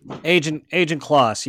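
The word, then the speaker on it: Ajan Kloss: Agent Kloss, is that correct? – Agent Kloss, yeah.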